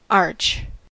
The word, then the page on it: arch: American English